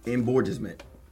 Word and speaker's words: embourgeoisement: Embourgeoisement.